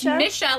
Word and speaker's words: Misha: It's Misha, like, I'm gonna miss ya.